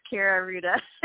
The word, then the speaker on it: Kaira Rouda: But it is Kaira Rouda.